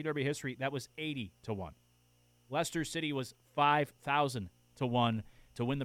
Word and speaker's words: Leicester: and I know they pronounce the name different than how it looks. I think is it Leicester City? I'm not into soccer. – Leicester City. – Yeah, it is Leicester City.